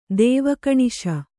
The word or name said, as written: ದೇವಕಣಿಶ